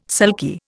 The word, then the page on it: sulky: Indian